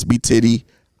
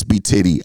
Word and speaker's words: B Tidy: It's B Titty, ITSBTIDY. My name actually spelled B Tidy on social media. I really started realizing, like, damn, I spelled my name wrong. But yeah. – I don't like putting the two D's, so it's B Titty, ITSBTIDY.